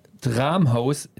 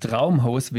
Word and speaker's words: Dramhaus: Dramhaus, nicht Traumhaus, wie wir immer dachten, falsch ja.